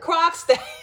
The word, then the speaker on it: Krogstad: Now, what was not clear to me, and this is I'm gonna call him Mr. K because I keep screwing up his name. I'm gonna say it wrong. Korsgaard. I'm sorry. – Krogstad. Krogstad.